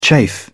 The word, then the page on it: chafe: British English